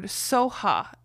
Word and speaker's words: Swaha: and but I'd also see it with the word Soha instead of Swaha Soha, spelled S O H A. And so I had to go looking. I'm like, why is that? And it's just because of differences in pronunciation with uh, the Buddhists. They just pronounce the words differently because um, of their accents or whatever it is. Um, Swaha is one of them. They pronounce Soha. And then um,